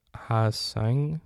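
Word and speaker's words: Ha-seong: Out of Korea, it's Ha-Sing. I feel bad for pronouncing this name wrong. Ha-Sung Kim, a 25-year-old shortstop, 30 home runs, 24 doubles, and 137 games.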